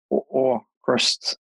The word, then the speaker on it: AVKRVST: I mean, that's, we kind of, at first we thought about that we wanted now to be pronounced as just the second V as a U. – Yeah. – So like Aukrust.